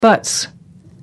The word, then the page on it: buts: British English